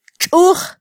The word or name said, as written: txuj